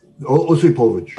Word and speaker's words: Osipovich: Uh, the Sukhoi uh, 15 interceptor pilot, Major Osipovic, I believe is that maybe how you pronounce it, uh, Osipovich.